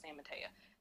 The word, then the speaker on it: San Mateo: But they say San Mateo. That's how they pronounce it.